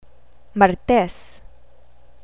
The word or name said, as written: martes